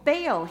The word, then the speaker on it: Baal: Back then, the most competitive god for the people's attention was a god called Baal. I'm saying it right now, thank you, Piper. Called Baal.